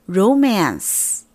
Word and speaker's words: romance: 名词发音是这样子的， romance 重音在前面，跟我再读一遍， romance。